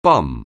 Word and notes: bum: anglais

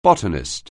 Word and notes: botanist: anglais

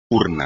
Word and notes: urna: espagnol

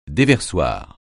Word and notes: déversoir: francais

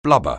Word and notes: blubber: anglais

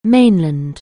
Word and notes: mainland: anglais

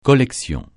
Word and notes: collection: francais

